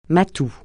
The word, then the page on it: matou: francais